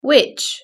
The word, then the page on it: witch: British English